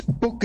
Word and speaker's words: Buch: It's Buch is the German name, and I'm originally German.